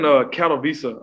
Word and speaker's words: Katowice: I'm in Katowice. I'm in south of Poland right now. They pronounce their W's as V's.